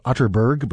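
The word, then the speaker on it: Atterberg: It's spelled Otterberg, but pronounced Otterberg, just in case you were Googling.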